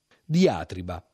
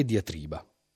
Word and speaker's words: diatriba: Il DOP, il dizionario di ortografia e pronuncia, consiglia diatriba meglio che diatriba. Ma un fortunato manualetto della Eupli, si dice o non si dice, manualetto consultabile anche online nel sito del Corriere della Sera, la pensa diversamente.